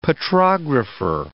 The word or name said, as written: petrographer